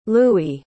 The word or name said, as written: lewie